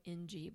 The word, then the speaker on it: Ng: It's spelled N-G, but it's Ng.